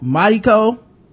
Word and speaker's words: Mariko: And in Japanese, they don't really pronounce the R like that. It's more like Mariko without stopping to say that R.